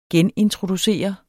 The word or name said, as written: genintroducere